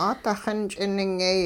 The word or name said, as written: 'aadixin ch'iningyay